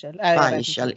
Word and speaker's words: Wiechel: Bland annat Björn Söder och vad heter han, Markus Wiechel. Uttalar man så? – Weichel.